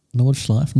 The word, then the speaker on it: Nordschleife: No, well, I tell you what, they've run a simulation around Nordschleife. How do you say it? – Nordschleife, Nürburgring.